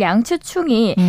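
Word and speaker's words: 양치충 (楊紫瓊): 12일 미국 로스앤젤레스 돌비 극장에서 열린 제95회 아카데미 시상식에서 양자경 씨 중국식 발음으로는 양치충이 음.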